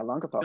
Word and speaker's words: Olongapo: Olongapo. Am I pronouncing that right? – Correct. Yeah, Olongapo.